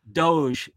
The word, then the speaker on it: DOGE: Doge, yeah. – Doge,